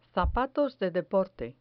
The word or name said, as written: zapatos de deporte